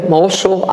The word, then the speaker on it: Mosul: It is pronounced M-O-S-U-A-L, Mosul, Iraq is where you would find it if you're going to look for Nineveh today, okay?